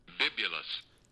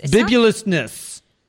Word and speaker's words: bibulousness: Is how it's pronounced. – Bibulous. – That bibulous. – Bibulousness. Bibulousness.